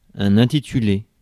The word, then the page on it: intitulé: France; /ɛ̃.ti.ty.le/